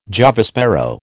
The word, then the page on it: Java sparrow: American English